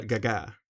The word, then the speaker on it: Gaga: Vi säger man ändå Lady Gaga? – Det är, ja, Gaga. – Det är det bästa sättet att uttala det på.